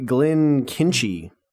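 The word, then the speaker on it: Glenkinchie: I've got the Glenkinchy 12-year Lowland whiskey. Just smells delightful. – I think your pronunciation was pretty good there.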